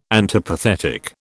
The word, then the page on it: antipathetic: British English